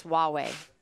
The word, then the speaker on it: Huawei: And you are also correct that it is pronounced Huawei. I did not know that.